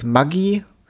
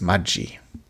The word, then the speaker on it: Maggi: Und sein Vater ist ein italienischer Einwanderer aus der Lombardei, ist also in die Schweiz gekommen und daher auch die Aussprache des Namens. Also wir in Deutschland kennen den Namen eher als Maggi, beziehungsweise die Produkte, aber der Herr ursprünglich hieß Maggi.